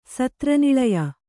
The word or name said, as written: ಸತ್ರನಿಳಯ